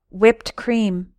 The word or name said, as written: whipped cream